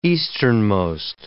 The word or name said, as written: easternmost